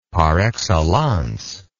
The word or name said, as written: par excellence